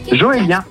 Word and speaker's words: Joélien: Ça se prononce comment ton prénom? – Joélien, J-O-E-L-I-E-N.